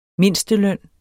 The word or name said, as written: mindst